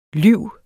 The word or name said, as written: lyv